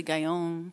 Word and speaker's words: Gaillon: It's actually a French name, like Sauvignon or Filet Mignon, Julie Gaillon.